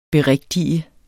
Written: berigtige

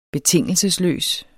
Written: betingelsesløs

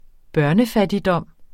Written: børnefattigdom

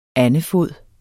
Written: andefod

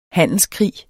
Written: handelskrig

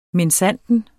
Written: minsandten

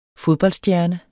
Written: fodboldstjerne

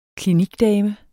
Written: klinikdame

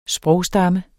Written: sprogstamme

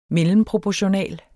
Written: MELLEMPROPORTIONAL

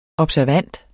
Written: observand

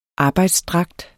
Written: arbejdsdragt

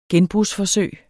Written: gen-

